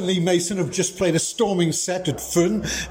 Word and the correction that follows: Ffwrn: I probably pronounced that wrong here in Aber Jazz Festival in Fishguard. Lowri, welcome to Pure West Radio. How do you pronounce it? – Ffwrn.